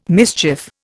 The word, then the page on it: mischief: Indian